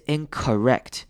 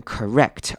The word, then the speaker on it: incorrect: 好，这边两个字要注意的是 incorrect 跟 correct，它的 O 都是念成轻音，所以是 incorrect 跟 correct，而不是 incorrect。